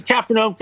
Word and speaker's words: Capharnaum: Capharnaum, yeah. Is that the way you pronounce it? – Capharnaum, Capharnaum, whatever, dude.